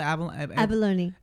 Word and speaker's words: Abalone: You have Rancho Palos Verdes Rolling Hill Estates with the Avalon Cove. Is I'm saying that right? – Abalone. – Abalone Cove? Mm-hmm.